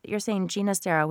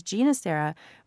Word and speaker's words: Ginastera: So you're saying Ginastera, which is how he wanted it to be pronounced later in life because of his roots. He had, I believe it was the Catalan roots, right? And that's where his name came from.